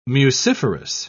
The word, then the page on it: muciferous: British English